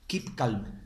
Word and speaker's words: Keep calm: Pepe, corrígeme si lo digo mal, ¿eh? Keep calm. – Bien dicho.